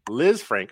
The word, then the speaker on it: Lisfranc: Liz, Liz, Lisfranc. Frank. – Lisfranc.